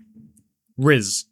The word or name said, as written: Rizz